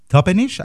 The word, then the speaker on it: Toppenish: Ella estudió en Yakima, en Toppenish, ¿lo dije bien? Sí.